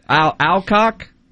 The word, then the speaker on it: Alcock: Alcock? Is that how you say it? – Mm-hmm.